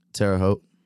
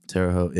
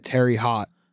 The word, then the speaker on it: Terre Haute: How do you pronounce the name of the town you're from? – Terre Haute. – Terre Haute. – Terre Haute, yeah. – So I've been calling it Terry Hot. – Terry Hot. No, you have not.